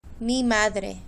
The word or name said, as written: mi madre